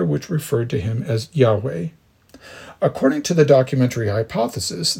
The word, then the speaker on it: Yahweh: At other points in the text, God is referred to by the Tetragrammaton, the four Hebrew letters, YHWH, and is usually pronounced something like Yahweh, but rendered as the Lord in English text, and typically in all capital letters.